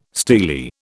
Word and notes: steely: British English

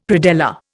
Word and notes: predella: Indian

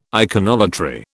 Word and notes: iconolatry: British English

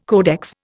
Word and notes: codex: Indian

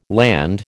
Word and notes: Land: American English

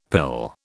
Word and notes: pill: British English

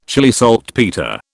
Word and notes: Chile saltpetre: British English

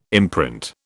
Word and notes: imprint: British English

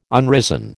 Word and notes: unrisen: American English

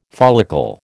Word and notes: follicle: American English

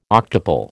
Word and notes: octuple: American English

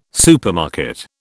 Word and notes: supermarket: British English

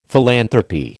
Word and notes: philanthropy: American English

